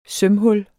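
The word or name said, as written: sømhul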